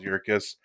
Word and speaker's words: Yerkes: Yeah, so Charles Yerkes.